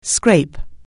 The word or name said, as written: scrape